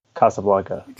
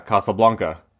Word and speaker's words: Casablanca: I think, so you may have found too, it is actually from the Spanish. So I guess we should pronounce it Casablanca. – Casablanca. Yeah.